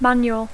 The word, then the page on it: manual: English